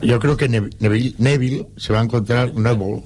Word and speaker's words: Neville: Es Neville.